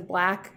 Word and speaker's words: 6LACK: I think his name is Black. It's spelled 6LACK.